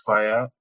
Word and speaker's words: Faya: Uh, kudos to you and the whole team, DG Uh, so basically, uh, my name is Moses Faya, F-A-Y-A, not F-I-R-E. But again, you can pronounce it as Fire.